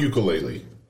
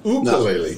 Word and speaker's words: ukulele: Ever since I introduced you to the ukulele, it's not ukulele, it's ukulele. No.